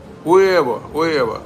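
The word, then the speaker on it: huevo: Что такое узво? Уэво. Вот это вот то, что мы подумали, пишет, читается уэво.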